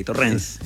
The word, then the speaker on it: Torrens: Ah, era con acento en la E y Torrens.